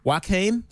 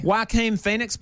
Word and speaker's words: Joaquin Phoenix: Harlequin Phoenix played. Joaquin. Sorry, I say that wrong every time. – You do. – Joaquin Phoenix played in Gladiator.